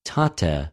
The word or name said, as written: τοτε